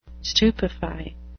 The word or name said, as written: stupefy